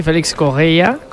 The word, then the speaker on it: Félix Correia: A pronunciarlo bien, Stassin, como nos han apuntado, y Félix Correia, como dice José, con muy buena pronunciación portuguesa.